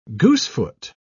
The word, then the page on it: goosefoot: British English